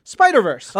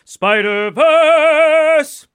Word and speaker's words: Spider-Verse: all off spider-verse uh, i need to correct your pronunciation again that's actually pronounced spider-verse I am not replicating that because I can't.